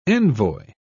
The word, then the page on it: envoi: British English